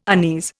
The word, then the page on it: unease: Indian